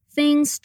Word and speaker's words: things: Come on, let's practice the TH pronunciation. Put your mm. tongue between your teeth to say the TH and make your N and G sounds very subtle. It's things, not things. Repeat things. Things.